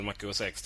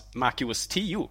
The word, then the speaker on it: Mac OS X: Och, eh, och där måste jag då avbryta som vän av ordning och konstatera att Apples operativsystem uttalas MacOS 10, inte MacOS X. X är en romersk siffra.